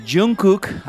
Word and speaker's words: Jungkook: Estoy hablando de Jungkook, así se pronuncia.